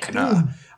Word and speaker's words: Heckna: Hecna. I'll spell it for you. – Yeah. – H-E-C-K-N-A with an exclamation mark at the end.